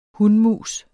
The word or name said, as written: hunmus